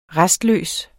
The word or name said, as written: restløs